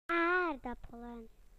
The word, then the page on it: potatoes: British English